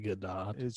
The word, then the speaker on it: Gadot: I think it might be Gadot. – She's Israeli. It's not French.